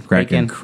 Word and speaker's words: Kraken: Kraken. – Kraken. – Kraken, yeah. So that's, like, that's not a long A. That's a soft Kraken encounter.